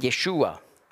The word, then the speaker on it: Yeshua: Und es ist so, gerade wenn man mit Orthodoxen spricht über den Messias, auf Hebräisch sagt man Yeshua. Jesus ist einfach die griechische Aussprache von Yeshua, das Gleiche. Aber mit Juden sagt man Yeshua. Und dann wird man feststellen, dass der orthodoxe Gesprächspartner, der sagt immer Yeshu.